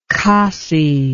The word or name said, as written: Khasi